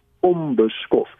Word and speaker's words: onbeskof: En dis meer moeite as om te sê onbeskof maar die mm klank die n klank word ook met albei lippe uh, uitgespreek dis ook 'n bilabiale klank so is makliker om van die m na die b te gaan en daarom sê ons onbeskof nou dit is net 'n uitspraakverskynsel en die spelling bly nog steeds onbeskof um,